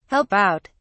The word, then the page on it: help out: British English